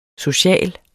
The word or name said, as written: social